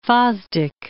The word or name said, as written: Fosdick